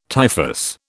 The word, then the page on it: typhus: British English